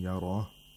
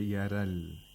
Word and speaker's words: يرى: Okay, we have the ra in yara, which is pronounced with tafkhim, with thickness, as opposed to thinness. So we have to say yara, yara al-insanu. We should not say yaral, yara al-insanu. In that way, we would have read it with tarqiq, which would be incorrect.